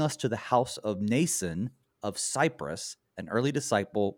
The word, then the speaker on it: Mnason: here's a name for you, M-N-A-S-O-N, Mnason. Nobody will pronounce it right for the rest of his life, but Mnason, he's a Cypriote.